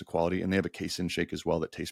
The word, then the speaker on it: casein: casein.